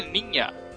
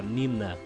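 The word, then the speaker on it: Niña: O Ninha se pronuncia Ninha. Errinhos comuns entre os brasileiros sem muita intimidade com o castelhano. – É, falar Nina...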